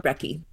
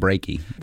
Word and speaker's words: brekkie: B-R-E-K-K-I-E. – Yeah. Another spelling that's common is B-R-E-K-K-Y. And in this case, the spelling is without the A, and it's very much about avoiding the pronunciation breaking. – We don't want to call it breaky.